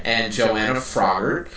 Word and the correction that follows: Froggatt: uh, Froggatt from um, what's that show?